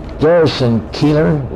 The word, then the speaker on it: Garrison Keillor: Garrison Keillor, is that how you'd pronounce it? – Yes.